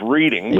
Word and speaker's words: Reading: Oh, well, that would be take a ride on the Reading, often mispronounced Reading, but Reading.